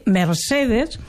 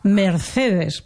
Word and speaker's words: Mercedes: I és que la gent, especialment aquí a Vinaròs, ho he observat, que la C, que aquí sempre s'havia pronunciat com a S, perquè el català no té el so de C, això no existeix. Pues ara resulta que tots ho pronuncien com si fos en castellà. En comptes de dir Mercedes, com s'ha dit tota la vida, ara diuen Mercedes, per posar un exemple que va en la mateixa línia del que tu dius, no?